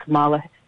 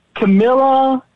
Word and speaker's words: Kamala: But then, you know, yesterday, Joe Biden announced that Kamala? – Yep, rhymes with Pamela. – Camilla?